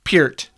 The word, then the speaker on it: Peart: Why do you call him Peart? – Because that's how he pronounces his name.